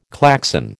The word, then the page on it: Klaxon: American English